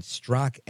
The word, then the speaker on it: Straka: Our guest is Brandon Strock, S-T-R-A-K-A. Like Straka, silent A, Brandon Strock, who is the founder of the Walkaway campaign.